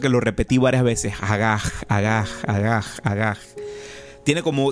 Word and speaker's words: hagah: esa palabra en el original que fue escrito, en el hebreo original que fue escrito, es la palabra agaj. Se escribe H-A-G-A-H. Agaj, agaj, agaj, agaj.